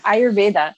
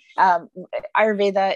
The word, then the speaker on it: Ayurveda: Ayurveda. Ayurveda is an ancient Indian healing art form.